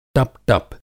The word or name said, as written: टपटप